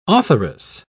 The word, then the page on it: authoress: British English